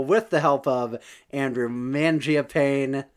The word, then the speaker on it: Mangiapane: But Mary Berry with the assist on the pronunciation there. He is Andrew Mangiapane in our hearts and minds.